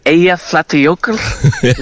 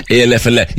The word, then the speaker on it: Eyjafjallajökull: I have forgotten how to pronounce the last big one. Help me here. – It's like Eyjafjallajökull is the big one. – Eyjafjallajökull.